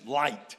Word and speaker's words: Lyte: This hymn was written by a man named Henry Francis Light. His name is spelled L-Y-T-E.